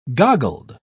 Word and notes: goggled: British English

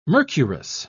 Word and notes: mercurous: British English

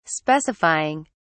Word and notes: specifying: British English

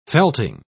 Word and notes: felting: British English

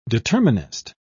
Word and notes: determinist: British English

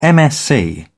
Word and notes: msc: British English

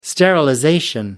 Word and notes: sterilization: American English

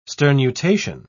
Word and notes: sternutation: British English

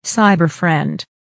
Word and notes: cyber friend: American English

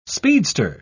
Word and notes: speedster: British English

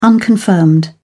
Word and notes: unconfirmed: British English

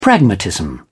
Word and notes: pragmatism: British English

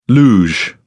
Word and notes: luge: British English

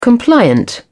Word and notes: compliant: British English

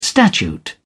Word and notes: statute: British English

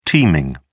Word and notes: teaming: British English

